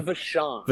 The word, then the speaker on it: Vachon: I'm going to mispronounce the last name. Vachon. – Luna Vachon. – Vachon, thank you.